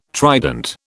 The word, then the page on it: trident: British English